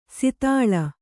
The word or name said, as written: ಸೀತಳ